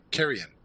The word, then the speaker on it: Carrion: And Carry On, am I saying that right? Carrion. Carrion.